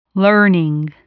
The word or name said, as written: learning